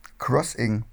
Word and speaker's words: Xing: Ja, das hat jetzt mit dem Jobwechsel ein bisschen abgenommen ähm, und auch auf so diesen Berufsplattformen wie LinkedIn und Xing oder Crossing, wie ich letztens erfahren habe, wie man das eigentlich ausspricht, äh, heißt ich nicht Hummel und da ist auch kein Hinweis drauf.